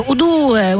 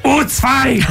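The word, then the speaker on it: U2: Jeszcze a propos YouTube, ja spędziłam sporo czasu kiedyś we Włoszech i bardzo mnie bawi jednak, jak Włosi mówią Udue, mówi o w ogóle Udue, będzie koncert Udue. To jest tak śmieszne jednak, jak w różnych krajach zupełnie inaczej wymawiają nazwy zespołów. – No to widzisz, ja kiedyś pojechałem na drugą stronę Odry i usłyszałem Ucwaj!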